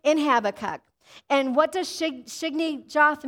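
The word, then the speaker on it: Shigionoth: It's so small, but it says this, Habakkuk 3 verse 1, a prayer of Habakkuk the prophet on, let me say this right, Shigginath. Everybody say Shigginath.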